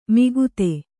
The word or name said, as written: ಮಿಗುತೆ